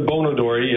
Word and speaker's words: Bon Odori: It's the Bon Odori, yes, sir.